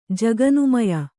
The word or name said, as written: ಜಗನ್ಮಯ